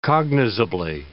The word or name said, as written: cognizably